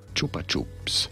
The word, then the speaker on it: Chupa Chups: Have a listen to how it's pronounced. – Chupa Chups. – Sorry? – Chupa Chups. – Chupa Chups. – Chupa Chups. – That's how they say it in Spain and a lot of other countries around the world.